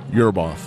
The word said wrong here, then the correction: Ueberroth: Yurb, how do you how do you say his name? Ueberroth to discuss his gambling.